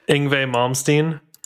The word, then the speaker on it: Yngwie Malmsteen: Yngwie Malmsteen.